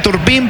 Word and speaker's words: Turpin: Se escribe Turpin, pero se pronuncia Turpin.